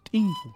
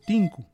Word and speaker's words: tinku: Primero hay que empezar aclarando, em, en las ciudades nosotros solemos decir muchas veces tinku. Sin embargo, no, no es así, ¿no? La forma eh, correcta de pronunciar es tinku.